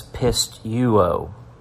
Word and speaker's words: pisteuo: In John 14:12, the Greek word behind the English word believe is pisteuo, translated as pisteuo and phonetically phonetically pronounced as pisteuo.